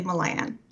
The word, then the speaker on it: Milan: And I said, well, I usually say Milan.